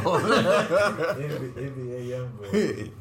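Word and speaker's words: NBA YoungBoy: You can't even say the name right, so I know we too old. – NBA young boy. – Yeah. There you go.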